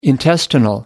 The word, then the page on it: intestines: American English